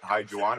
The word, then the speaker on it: Juan: But by the way, hi, Juwan. Am I pronouncing that correctly? – Hey, perfect.